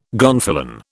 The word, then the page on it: gonfalon: British English